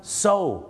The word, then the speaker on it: sew: so